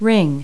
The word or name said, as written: ring